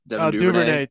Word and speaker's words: Duvernay: This is going to be Duvernay, I believe. – Duvernay.